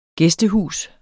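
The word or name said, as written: GÆSTEHUS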